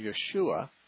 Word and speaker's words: Yeshua: And that Hebrew pronunciation, Yeshua, is transliterated in the Greek New Testament as Jesus. So yeah, Jesus. – Jesus, yeah. And so it's Yeshua is the Hebrew and Jesus is the Greek.